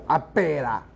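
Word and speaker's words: Abélard: 阿伯拉呢，因为他是法文发音，所以重音在那个 b 上面哈，所以严格来讲哈，这就很搞笑，他的名字叫阿贝拉这样，一加贝拉，加咒啊，不是什么叫阿贝拉，所以要翻成阿伯拉，不是阿拉伯哈。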